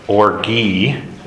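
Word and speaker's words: orgi (ὀργή): The second is the Greek word orgi, O-R-G, long E, which is long-lived anger.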